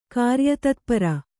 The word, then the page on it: ಕಾರ್ಯತತ್ಪರ: kāryatatpara